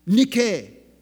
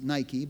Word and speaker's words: Nike: Nike. We pronounce it Nike, but that's because we don't know Greek. Nike.